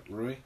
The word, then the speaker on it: Rui: So, if we got Rui, that's how you pronounce it, Rui.